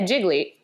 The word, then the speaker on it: Gigli: It's spelled G-I-G-L-I. – Yeah, Jiggly.